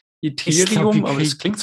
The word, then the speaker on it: Ethereum: Unser heutiges Thema Ethereum, ETA, Ethereum, wie sollen wir es aussprechen? E e ETH? – Ethereum, ich sage immer Ethereum.